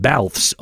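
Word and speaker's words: Balthvs: But first, let's check out your song of the day, which today comes from a group called Balfs, although it would be spelled in a way that maybe you would have trouble with knowing that that's how it's pronounced. So it's B-A-L-T-H-V-S, kind of like the band Churches does.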